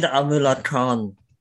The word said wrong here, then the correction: Amanullah Khan: come, sorry if I said his name wrong, but how did he come to power? – Amanullah Khan, how did he come to power?